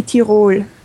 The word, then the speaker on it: Tyrol: And first of all, am I pronouncing it anywhere close? The Tyrol? The Tyrol? How do you say it? Well, in German it would be Tyrol. – Tyrol. – Okay. But if you pronounce it the English way, that's fine.